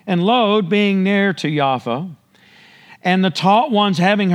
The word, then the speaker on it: Jaffa: And in Yafo, or Jaffa, is how it's pronounced currently, there was a certain taught one named Tabitha, which means Dorcas.